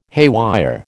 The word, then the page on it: haywire: American English